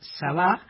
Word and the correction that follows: Sabaj: Sabaj, Sabaj, hay que pronunciar la H final.